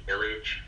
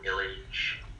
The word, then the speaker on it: Illidge: Illige. Last name is spelled I-L-L-I-D-G-E. On Facebook, I am Joe Illige. And on Instagram, I am IllMaster1, and that's basically, and on LinkedIn, I'm Joseph Philip Illige,